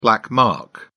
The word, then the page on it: black mark: British English